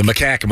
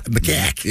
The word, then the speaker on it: macaque: What is it? It's macaque. – The macaque monkey, yeah. – Okay, I got macaque. – Yeah. – Okay. I got this. – Rhymes with backpack.